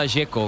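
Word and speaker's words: Džeko: Porque de Z, ¿cómo se pronuncia? – Dzeko. Bruno dice que es Dzeko. – Como tirando a J, Dzeko o algo así.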